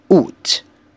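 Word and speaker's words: Août: 八月 Out, out.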